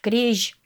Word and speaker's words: крезь: Крежь. Крежь. – Крежь. А пишется как крезь. Буква «з» и мягкий знак в сочетании нашем в новодмурском языке читается как «ж».